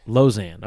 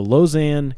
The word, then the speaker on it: Lausanne: But it was basically, I just looked up the pronunciation. Lausanne, looks like. – Lausanne. Okay. So the Lausanne covenant, I thought it was called the Lausanne statement.